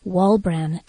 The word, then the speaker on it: Walbran: Shannon, S-H-A-N-N-O-N, Walbran, W-A-L-B-R-A-N.